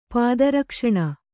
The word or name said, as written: ಪಾದರಕ್ಷಣ